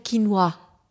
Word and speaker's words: Quinoa: Auf Französisch würde man sagen Quinoa. Es ist wirklich ein Moi am Schluss.